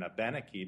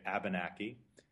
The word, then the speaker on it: Abenaki: And it's really clearly illustrated in Abenaki because there are four syllables. So we go three syllables back and we have Aben and then Aki. Pului Hidit would be another word like two that they escaped. Pului Hidit. And if we were to mispronounce, we get Abenaki. That's just pronouncing it more anglicized.